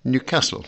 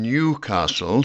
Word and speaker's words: Newcastle: I was born in Newcastle, with the stress on the second syllable and a short E in the first. Not in Newcastle, stressed on the first syllable with its long E.